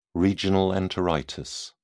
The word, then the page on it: regional enteritis: British English